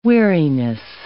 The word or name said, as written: weariness